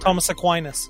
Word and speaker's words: Aquinas: Probably saying that wrong. Oh, I said it right. – Thomas Aquinas.